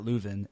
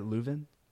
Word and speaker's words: Leuven: Do you think it, the way they do theology at Leuven, am I saying that right, Leuven? Or is it Louvain? – You can say Leuven if you're a Dutch speaker, and you can say Louvain if you're French, and this is somewhat contentious with the people there because for centuries, in fact, French was the language of education.